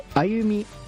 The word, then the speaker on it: 通学路: はい、はい、一番歌い出しは、通い慣れたこの道も語り合ったあの日々もきっとどれもが思い出の歩みということで、歩みというのが通学路と書いて歩みと読ませるというのは、